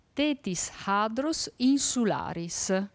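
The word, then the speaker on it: Tethyshadros insularis: Si dice Tetis Hadrus Insularis. – Provate tutti insieme. Sono bravissimi. – E come è fatto il Tetris Hadrus Insularis?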